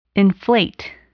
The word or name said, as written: inflate